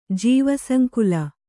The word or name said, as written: ಜೀವಸಂಕುಲ